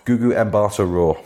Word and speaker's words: Gugu Mbatha-Raw: Gugu Mbatha-Raw. – That's the one. – Yes. – It was between those two. – Hang on. I could have lived in a world in which Gugu Mbatha-Raw was Black Canary.